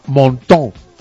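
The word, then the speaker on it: Menton: que se llama Montón. Montón. – ¿Cómo se llama? – Montón. Se escribe mentón, pero se dice Montón.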